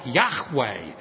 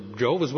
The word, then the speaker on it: Yahweh: And by the way, in the Hebrew, it's not even pronounced Jehovah. It's more like Yahweh. So the Jehovah's Witnesses don't even know what they're talking about.